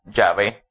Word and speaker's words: Yahvé: Y en hebreo moderno se pronuncia Yahvé.